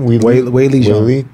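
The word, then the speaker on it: Weili Zhang: Whaley Zhang.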